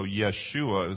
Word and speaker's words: Yeshua: That's the way, in fact, they don't have a J sound, they have a Y sound, so Yeshua is the way you would pronounce his name.